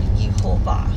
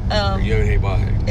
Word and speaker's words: Yehovah: Because I call him, because that's direct pronunciation from the Hebrew letters, or Yehovah. – Yehovah.